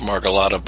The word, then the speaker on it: Margolotta: I've heard it pronounced Margolotta by Stephen Briggs, and that's good enough for me.